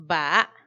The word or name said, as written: baa